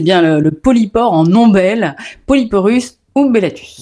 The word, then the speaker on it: Polyporus umbellatus: Alors effectivement, le polypore en ombelle, son nom latin c'est Polyporus umbellatus. Enfin, Polyporus umbellatus, hein, si vous voulez exactement voir la prononciation.